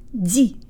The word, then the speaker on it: люди: Let's do the second syllable. Jaw forward, tip of the tongue behind the bottom teeth, lips rounded and protruded. Ди. Ди. Not D, but ди.